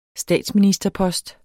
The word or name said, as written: statsministerpost